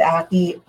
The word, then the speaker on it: Aki: How do you say Addy? – Yeah. Aki. A-K-I. A-K-I.